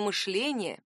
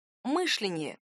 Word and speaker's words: мышление: Нет, к политике ударение в этом слове не имеет никакого отношения. Еще в конце 50-х в словаре-справочнике «Русское литературное произношение и ударение» под редакцией Аванесова и Ожегова было зафиксировано литературное мышление и допустимое мышление. Два варианта ударения можно найти даже в словаре «Дали», а это, между прочим, 19 век.